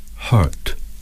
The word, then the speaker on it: hurt: Bird, bird, hurt, hurt. – Some speakers of British English do not sound the r in this word.